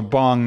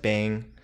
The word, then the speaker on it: bhang: So bong, not like the bong you smoke out of. – BHANG. – Okay. – So some people pronounce it bang. Mm-hmm. There's actually a company that makes edibles that are called bang.